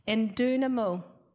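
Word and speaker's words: endunamo: So that word strong is pronounced, it comes from two words, and it's pronounced in dunamo. It's made up of two words, and the first word is en, and it means into. And the second word comes from the word dunamis, which means explosive strength, the ability and the power.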